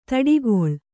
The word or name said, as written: ದಾಟುಗಾಲು